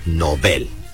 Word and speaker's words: Nobel: Ambas palabras son agudas, por lo tanto su pronunciación adecuada es novel.